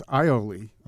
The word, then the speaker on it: aioli: So it's not by aioli. – My, by my friend Brett Beal. – Okay. – Uh, it's aioli.